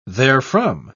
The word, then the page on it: therefrom: British English